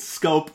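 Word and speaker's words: Schoop: Shoop. Like who are we? – It's Scope, but Shoop is funny.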